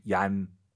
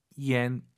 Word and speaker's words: 烟: 그리고 정말 많은 초급자분들이 이 YAN을 딱 보시면 얀 떠올라요. 얀 아니죠. Y를 항상 마음속으로 I로 바꾸세요. IAN, EN이잖아요. – 얀. – 얀, 네. 정확한 발음은 yen 이로 시작하는 거예요. yen 이렇게 발음하면 좋을 것 같습니다.